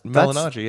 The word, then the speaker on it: Malignaggi: So if you don't know, if you listened a couple of weeks ago, you would have known that Paulie Malignaggi has been in the McGregor camp. – I think you pronounced his name right. That's Malignaggi, yeah.